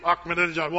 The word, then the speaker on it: Ahmadinejad: How do you say that name? Ahmadinejad. Well, I just remember, I'm in a jihad. That's kind of how you remember his name. If you say that, you're pretty close.